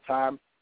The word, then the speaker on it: Thyme: And also, like, my Facebook and everything, and my Twitter is Justin Time, and it's spelled T-H-Y-M-E.